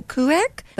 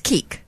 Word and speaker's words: Kueck: Well, then you spell it K-U-E-C-K, so Kuek, but it's Keek.